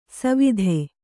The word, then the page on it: ಸವಿಧೆ: kannada